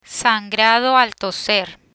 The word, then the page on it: sangrado al toser: spanish